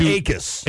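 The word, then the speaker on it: Jackus: Right, and I forget the name of the Gabriel Jakus? – Yeah. – Who was the... – I think it's Akis. – Akis. – Yeah. – The silent J.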